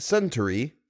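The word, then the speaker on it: centaury: It's pronounced Centauri, and it's a type of flower.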